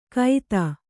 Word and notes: ಕೈತ: kaita

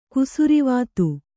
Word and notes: ಕುಸುರಿವಾತು: kusurivātu

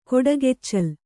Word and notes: ಕೊಡಗೆಚ್ಚಲ್: koḍageccal

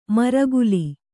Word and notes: ಮರ್ಕಲಿ: Kannada

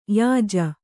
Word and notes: ಯಾಜಿ: yāji

